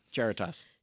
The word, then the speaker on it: Charitas: Now, past the Theater on the Lake, there's the, and correct me if I'm saying this wrong, is it the Charitas sculpture? – Yes, Charitas.